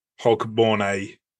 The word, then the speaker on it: Hogmanay: But firstly, how do you pronounce it? Is it Hogmanay, isn't it? – That's how I would say it. – Yeah, Hogmanay.